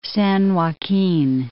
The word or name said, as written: San Joaquin